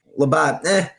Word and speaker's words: Labatt: Labatt, did I say it right? – Labatt Blue, Blue Canadian Pilsner, of course, first try.